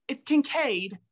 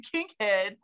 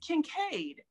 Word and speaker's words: Kinkead: So it's Kinkead. Everybody always calls me Kinkhead. So it's Kinkead. It's just Kinkade. Spelled the really Irish way.